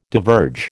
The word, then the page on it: diverge: American English